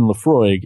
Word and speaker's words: Laphroaig: And even Laphroaig, if you tried to guess how Laphroaig was spelled, nobody sees if they go Laphroaig, Laphroaig. It's L-A-P-H-R-O-A-I-G.